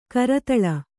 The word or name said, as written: ಕರತಾಲ